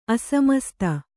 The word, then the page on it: ಅಸಮಸ್ತ: asamasta